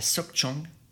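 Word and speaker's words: Sóc Trăng: Ces zones-là, je pense notamment à Chavin, à Sok Chong et à Chiton. Alors ça s'écrit T-R-A plus loin V-I-N-H, S-O-C plus loin T-R-A-N-G, ou Chiton, ça c'est carrément un district dans une province T-R-I plus loin T-O-N.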